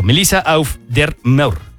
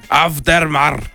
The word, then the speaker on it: Melissa Auf der Maur: Melissa Auf der Maur. – Sonó bien. ¿Cómo lo dijiste? – Auf der Maur. Fue como Final Decision.